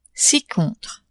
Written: ci-contre

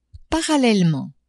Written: parallelement

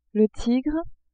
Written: tigre

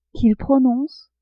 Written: qu'ils prononcent

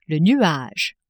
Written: nuage

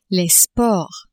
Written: sports

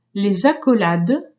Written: accolades

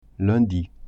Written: lundi

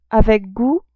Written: avec gout

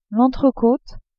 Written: entrecote

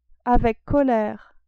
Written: avec colere

